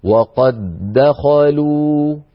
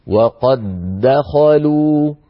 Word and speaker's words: وقد دخلوا: نلاحظ أن وقد آخرها دال ساكنة، دخلوا أولها دال متحركة، يعني اجتمع في النطق دالان، دال ساكنة تتلوها دال مفتوحة متحركة يعني، فهذا يقتضي أن ندغم، يعني ندخل الدال الأولى في الدال الثانية بحيث يصير النطق بدال واحدة مشددة هكذا. وقد دخلوا، أعيد وقد دخلوا.